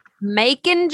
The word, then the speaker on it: Macon: So my number three was Macon. I think that's how you say it. Macon by Jason Aldean. – Macon, Georgia. Macon.